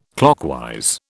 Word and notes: clockwise: British English

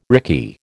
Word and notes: rickey: American English

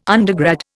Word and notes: undergrad: Indian

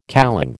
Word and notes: kalong: American English

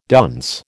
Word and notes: dunce: American English

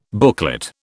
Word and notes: booklet: British English

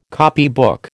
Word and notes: copybook: American English

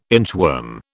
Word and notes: inchworm: British English

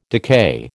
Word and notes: decay: American English

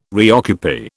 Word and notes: reoccupy: British English

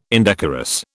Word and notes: indecorous: British English

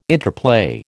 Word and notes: interplay: American English

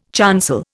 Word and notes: chancel: Indian

